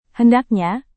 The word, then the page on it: hendaknya: Indonesian